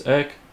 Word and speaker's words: Erk: Now, my name is spelled E-R-I-C. However, my nickname has been for many years Erk, E-R-K.